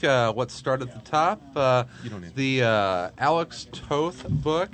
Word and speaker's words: Toth: No, it's Alex Toth.